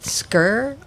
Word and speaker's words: skyr: It's a Icelandic recipe with skyr.